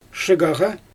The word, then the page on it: ʃɯɣaɣa: Xyzyl